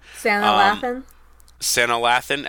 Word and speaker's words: Sanaa Lathan: Sanaa Lathan as Catwoman.